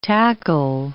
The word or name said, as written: tackle